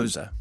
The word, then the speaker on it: Xhosa: Xhosa. – No, but it's XH. – Yes, and so it has a click. But it's a click. – I don't think you're expected to say Xhosa with a click unless you are indeed Xhosa.